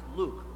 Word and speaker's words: Luke: No, it's pronounced Luke. Long you.